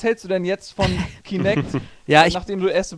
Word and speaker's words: Kinect: Unter anderem natürlich das ehemalige Projekt Natal, was jetzt Kinetic heißt. – Falsch, nee. Du hast das schon falsch gesagt. – Das habe ich falsch gesagt. – Kinect. Kinect, verdammt.